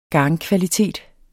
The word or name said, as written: garnkvalitet